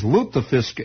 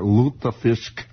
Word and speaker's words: lutefisk: That is lutefisk, L-U-T-E, with the little umlauts and little things, little crystal balls and chandeliers over the U there. Lutefisk. Lutefisk. The way it's pronounced.